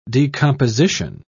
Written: decomposition

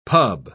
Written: pub

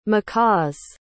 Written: macaw's

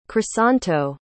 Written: crisanto

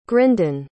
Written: grindan

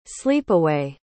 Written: sleepaway

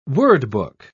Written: wordbook

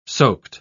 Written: soaked

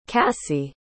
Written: cassie

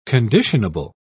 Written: conditionable

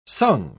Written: sung

